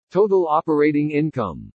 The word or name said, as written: TOTAL OPERATING INCOME